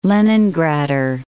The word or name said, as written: Leningrader